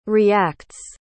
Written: reacts

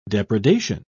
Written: depredation

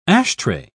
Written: ashtray

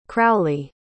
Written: crowley

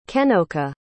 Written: kennocha

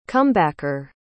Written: comebacker

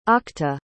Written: octa

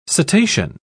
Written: cetacean

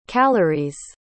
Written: calorie's

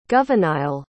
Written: goveniayle

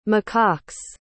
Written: macaques

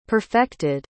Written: perfected

